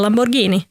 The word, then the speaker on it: Lamborghini: Det heter Lamborghini. Mm. Och det ser man, för det finns ett H efter G, vilket betyder att det ska uttalas som ett G.